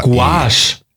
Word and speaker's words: Gouache: Dann liest du die anderen, G-U-A-C-H-E. G-O-U-A-C-H-E. – Gouache. – Gouache. – Gouache. – So spricht man es aus.